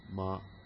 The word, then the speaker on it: dunamao: Looks like that in Greek. Dunamao. Short O, long O.